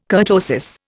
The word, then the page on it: kurtosis: Indian